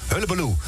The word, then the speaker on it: Hullabaloe: Hoebeloe. Ik ken dat nog goed uitspreken. Hullabaloe.